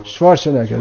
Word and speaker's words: Schwarzenegger: Schwarzenegger, that's how you pronounce it.